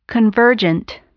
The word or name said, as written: convergent